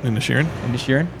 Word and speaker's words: Inisherin: It's on Crave, and it's called The Banshees of Inisherin, I think is how you say it. – Inisherin.